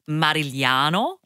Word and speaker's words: Marigliano: Unless we're pronouncing it in the fully Italian way, my relatives will not be really happy. So it's like, I'm meant to be saying it as Marigliano, but I'm okay.